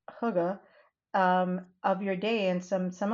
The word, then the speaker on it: hygge: It was, it's spelled H-Y-G-G-E. And I think it's like hygge.